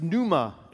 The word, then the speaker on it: pneuma: In the Greek, it's pneuma, and pneuma with a P, right? It's another silent P. The Greeks love their silent Ps.